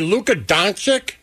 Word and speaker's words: Luka Doncic: Hey, Luka Doncic? – Yeah. – Is that how you say it? – Sure.